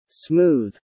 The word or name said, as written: smooth